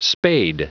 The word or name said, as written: spade